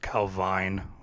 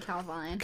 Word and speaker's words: Calvine: were snapped on August 4th, 1990, by two hikers on a hillside near Calvin, about 35 miles northwest of Perth in Scotland. – I think it's pronounced Calvine with the E at the end, but... – Calvine. – Calvine. Sorry. Calvine in Scotland, I guess.